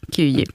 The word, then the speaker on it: Kyiv: gick över till Kyiv, om det nu är ungefär sådär som det uttalas. – Ungefär så, Kyiv.